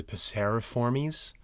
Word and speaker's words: Passeriformes: Um, but you know how like it's I thought it was passiforms, but it's really passeriformes.